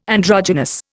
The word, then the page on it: androgynous: Indian